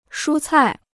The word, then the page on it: 蔬菜: shū cài